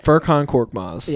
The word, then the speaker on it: Furkan Korkmaz: Say his name, right? – Furkan Korkmaz.